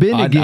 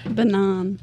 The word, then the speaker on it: benign: Binigan. – I know this one. – That's what I was Binigan. – Thinking. – Yeah, let's just talk about tumors. Binigan. – Benai. – Benay. – Benign.